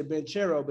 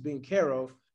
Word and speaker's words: Banchero: I'm going to say it right because a lot of people say Banchero, but it's actually Banchero.